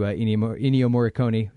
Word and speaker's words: Ennio Morricone: And another interesting note, at least the music is mostly attributed to Ennio Morricone.